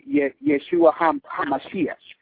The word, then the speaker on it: Yeshua HaMashiach: And if you were to say Jesus the Messiah, you would say Yeshua HaMashiach.